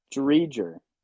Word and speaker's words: Driedger: Uh, How did you just pronounce his name? – Drydgar. You, was he on the Panthers last year? Was he that goalie that was on the Panthers last year? – Yes. – What? How do you say his name? – Driedger. – Close. I was close.